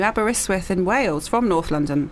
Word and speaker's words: Aberystwyth: Oh, no, yeah, you're right. Aberystwyth. Mm-hmm. Yeah, I'm just imagining a T where there isn't one.